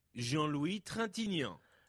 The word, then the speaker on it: Jean-Louis Trintignant: Jean-Louis Trintignant.